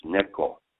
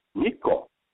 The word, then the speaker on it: Neko: She lived in Hawaii for a while, and then somehow she got the nickname of the Japanese word for cat, which is Neko. And N-E-K-O. But her parents they mispronounced it when they see it, you know, spelled out. And they call her Niko instead of Neko.